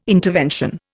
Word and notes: intervention: Indian

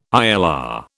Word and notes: ILR: British English

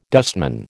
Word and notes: dustman: American English

